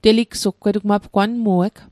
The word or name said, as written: teligsugg